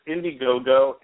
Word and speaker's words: Indiegogo: You're really close. It's Indiegogo, and what it is, it's a crowdsourcing service. So basically, it's a crowdsourcing campaign called Indiegogo.